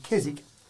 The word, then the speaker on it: Keswick: From a pronunciation point of view, I often say Keswick as a silent W, and I noticed you then said Keswick.